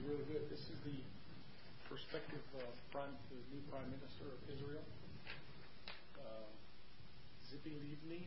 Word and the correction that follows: Tzipi Livni: is that how you say it? – It's Tzipi Livni is not actually the one who will be the prime minister.